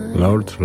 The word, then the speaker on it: Lorde: Lord, Lordy, pisze się Lorde. Taka to artystka.